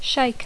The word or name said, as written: shake